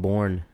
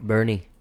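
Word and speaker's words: Boerne: I always thought that place was called Bourne. – Everybody does. It's spelled very bizarrely. – Bernie.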